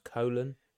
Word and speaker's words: Cologne: And a couple of weeks ago, they headlined at the Lanxess Arena in Cologne, which is just like a stone throw away from... – I think it's pronounced colon.